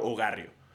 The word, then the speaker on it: Ogarrio: Está todos los primos hogarrio todos mis primos hogarrio porque para quienes no sepan, me llamo Diego Alfaro Hogarrio o-G-A-R-R-I-O. Un apellido muy complicado toda la pinche vida. No es complicado, pero la gente se apendeja. En cuanto le dices mi apellido Ogarrio la gente es como, ¿cómo se escribe?